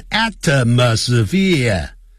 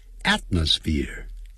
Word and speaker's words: atmosphere: 不要念成 atmosphere atmosphere at 那个 t 这样念出来的时候把它顶住